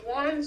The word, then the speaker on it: Juan: Yeah, J U A N, and that in that's a in Spanish the J is pronounced like an H, so it's Juan or Juan. So it's like. It looks like John, but it's Juan, yeah.